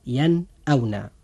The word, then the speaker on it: ينأون: وهذا الحرف أخي المسلم جاء بعد النون الساكنة، فهنا يجب الإظهار وننطق هذه الكلمة هكذا ينأونا.